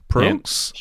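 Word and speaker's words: Proulx: Headed up by Marvel Avengers and Guardian of the Galaxy producer, now excuse my French, I think you pronounce it, Oliver... Pruelix? Pruelix? Yep. – Sure. – Sounds right.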